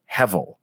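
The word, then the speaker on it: hevel (הבל): The Hebrew word behind it is pronounced hevel, and it's actually the same word where Abel, you could hear Abel, hevel, kind of, sort of, that's what his name means.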